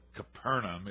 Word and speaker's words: Capernaum: That's "O Little Town of Capernaum," although Capernaum is the authorized pronunciation of it.